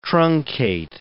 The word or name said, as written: truncate